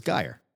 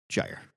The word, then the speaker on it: Gyre: And every time he said Gyre, I cringed a little bit because I thought it was Gyre. I'm wrong. It's pronounced Gyre.